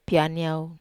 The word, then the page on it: pianiau: wamesa